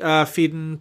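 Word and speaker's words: Phedon: I get the Fiden Papamichael. It's so weird that that's how you pronounce that name. At the Oscars, I was like, that's really, there's just no, like, little European trill on that at all.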